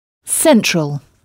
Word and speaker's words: central: Et remarquez que là encore, on frappe la syllabe qui vient avant la terminaison en « all », donc « central ».